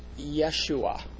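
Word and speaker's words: Yeshua (ישוע): The Joshua that I am talking about pronounces his name in the Hebrew as Yeshua.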